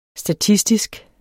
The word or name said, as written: statistisk